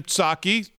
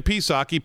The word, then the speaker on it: Psaki: Jen Psaki, Jen Psaki, P is silent, was in the briefing room, as she always is.